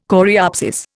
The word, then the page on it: coreopsis: Indian